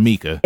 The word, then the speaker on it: Misa: Like, is it Misa or Mika? – It's Misa.